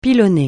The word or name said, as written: pilonner